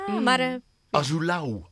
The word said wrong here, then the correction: Azulão: Azulau, Azulau. Zeg ik het goed? – Azulão.